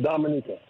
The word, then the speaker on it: Dominica: The natives, they pronounce it Dominica. – Dominica.